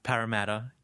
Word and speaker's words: Parramatta: Parramatta.